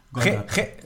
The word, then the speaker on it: Golgatha: Und als das dann alles ähm, äh, betont wird auf der ersten Silbe. Im Aramäischen. Wollte ich jetzt einfach mal. – Ne? – Wie heißt es denn dann richtig? – Golgatha.